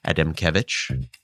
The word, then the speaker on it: Adamkiewicz: My whole medical career, I can't believe I've been saying it wrong. – Being in the low thoracic region, typically on the left from T8 to T12. – Which is anatomically important because that also happens to be where there's a less dense anastomotic network in that lower thoracic level. And again, that's the artery of Adamkiewicz. My medical school neuroanatomy teacher would be incredibly proud that I pronounced it appropriately.